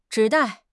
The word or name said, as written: zhi3 dai4